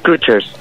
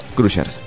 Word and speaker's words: Crushers: ¿Es de Crushers los Crushers o los Crushers? ¿Cómo se pronuncia? – Sí, no, los Crushers. – Crushers, perfecto. – Sí, es tal como suena ahí.